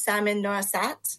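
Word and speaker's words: Samin Nosrat: I believe her name is Salmon Norissat.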